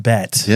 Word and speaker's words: Bette: We ended with Bette. – Yes. – Appropriately for this. So I had a couple other notably. – The E is silent.